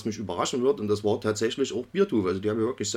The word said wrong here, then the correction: Beartooth: Beartooth, glaube ich, wird es ausgesprochen. Beartooth, ja, okay, gut.